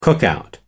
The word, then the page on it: cookout: American English